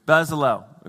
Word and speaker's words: Bezalel: As we've already seen in verse 30 of chapter 35, God has gifted this guy, Bezalel. Every time I go to say it, I'm like, am I going to say this right? Bezalel, okay?